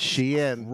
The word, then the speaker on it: Shein: Oh, Mary, it is Shein. – Jesus. – It's Shein.